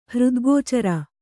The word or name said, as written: ಹೃದ್ಗೋಚರ